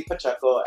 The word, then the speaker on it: Pacheco: Yeah, it's Randy Pacheco, and the pronunciation for our hospital in Hawaiian is Wahiawa General Hospital.